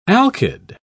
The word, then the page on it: alkyd: British English